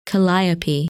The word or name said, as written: Kalliope